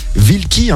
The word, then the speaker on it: Wielki: Alors toi qu'on connaissait Solitaire en production, tu signes cette fois ce single avec un autre producteur, Wielki, hein, on le prononce comme ça, hein, c'est ça.